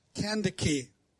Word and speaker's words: Candace: And it wasn't pronounced Candace, it was pronounced Candace. Candace, like candy with a duck in the middle.